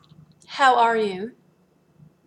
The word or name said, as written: how are you